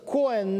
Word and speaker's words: Коэн: кстати, вот произношение Коэн на иврите, это священник, то есть есть среди бухарских евреев, например, фамилия Аронов, это все священнические фамилии.